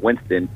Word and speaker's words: Winston: That's spelled J-A-M-I-L, C as in Charlie, and then Winston, W-I-N-S-T-O-N.